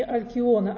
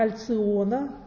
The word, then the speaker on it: Альциона: Мы говорим не Алкиона, а Альциона. Звезда называется Альциона.